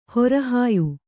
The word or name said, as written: ಹೊರಹಾಯು